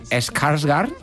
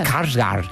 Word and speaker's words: Skarsgård: Tuvimos primero a Tim Curry en los años 90 y ahora tenemos a Bill Skarsgård, que parece que es una estantería de Ikea, pero no, es un actor. – Y qué bien lo pronuncias. – Skarsgård.